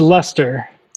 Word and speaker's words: Leicester: Did I pronounce Leicester right? it's Leicester. – Leicester.